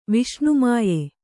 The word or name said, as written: ವಿಷ್ಣುಮಾಯೆ